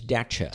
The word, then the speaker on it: Dacia: No, it's Dacia.